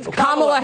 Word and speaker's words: Kamala: You're not allowed to criticize Kamala Harris or Kamala Harris or whatever. – Kamala Harris.